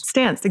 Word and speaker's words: STNCE: Am I saying that right, Stance? – Stance, exactly. – STNCE, for those that are listening.